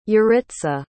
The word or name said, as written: yaritza